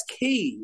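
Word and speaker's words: quay: That's key. It's not K. Oh, I see what you're saying. – Oh, so that's British-American thing. – Yes, it's a difference in pronunciation. I say standing on the edge of the key. Mm-hmm. I thought you meant as in K-sara-sara, you know, Q-U-E.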